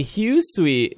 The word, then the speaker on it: Hisui: Many years ago, the land was called the Hisui.